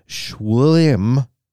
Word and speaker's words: Schwilm: Schwinn, or I said that wrong, it's Schwilm, S-C-H-W-I-L-M, versus Pennsylvania Sports.